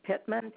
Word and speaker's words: Pitman: My first name is Kathy, and that's spelled C-A-T-H-I-E. Last name is Pittman, P-I-T-M-A-N.